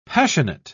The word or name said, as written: passionate